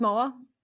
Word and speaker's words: Mauro: It's spelled, it's pronounced the way you spell it is M-O-R-R-O-W. But it's spelled the Italian way. So M-A-U-R-O. So you pronounce it as if you were going to spell it M-O-R-R-O-W.